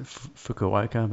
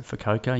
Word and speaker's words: Fukuoka: We pronounce it Fukuoka, but they seem to pronounce it Fukuoka.